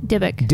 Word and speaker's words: Dybbuk: Is that how you say it? Dibbuk? – Dibbuk.